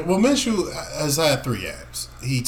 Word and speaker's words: Minshew: It's Minshew. Minshew. Like a cashew.